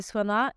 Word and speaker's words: Svana: au fait, euh, comment est-ce qu'on prononce ton nom Bah, exactement comme tu l'as prononcé, Swana.